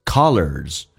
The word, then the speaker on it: colors: 第一个单词叫 color，打开口腔，在美式发音比较夸张一点到极限， car 轻轻的卷一下舌头， lers colors，元音后面加 s 发 z 这个发音。